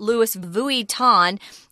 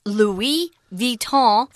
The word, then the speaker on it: Louis Vuitton: But if you want to know the real pronunciation, it's not Louis Vuitton. It's Louis Vuitton, French.